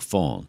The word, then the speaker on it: Faughn: his number is Fawn, F-A-U-G-H-N, by the way. – Okay. – F-A-U-G-H-N. Some people say, how do you spell that? T-H-A-T. His last name is F-A-U-G-H-N, Fawn, Mark Fawn.